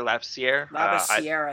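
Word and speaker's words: Labissière: Labissiere, I think it's pronounced.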